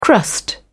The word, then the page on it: crust: British English